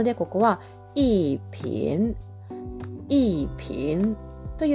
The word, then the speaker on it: 瓶: で、この場合、ピンという後に来るね、えー、ビンという、えー、文字。これは PING と発音記号で書きまして、第 2 世、下から上にずり上がる音になります。